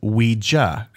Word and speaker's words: Ouija: You said Ouija. I think I used to say Ouija. The correct pronunciation is Ouija, and let me explain why people started manufacturing them in the 1890s.